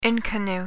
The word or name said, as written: inconnu